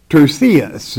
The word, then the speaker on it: Tertius: Tertius, that's the correct pronunciation of this name in the Greek, who wrote this epistle, salute you in the Lord.